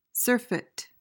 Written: surfeit

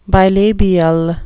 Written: bilabial

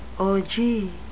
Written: ogee

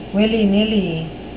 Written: willy-nilly

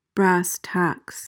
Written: brass tacks

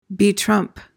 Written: betrump

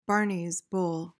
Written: Barney's bull

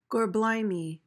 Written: gorblimey

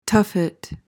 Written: tuffet

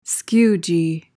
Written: skewgee

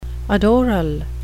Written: adoral

